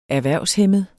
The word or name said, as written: erhvervshæmmet